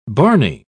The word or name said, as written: barney